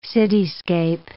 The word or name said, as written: cityscape